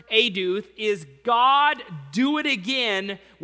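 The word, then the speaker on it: eduth (עדות): Eduth. It's spelled E-D-U-T, but pronounced eduth.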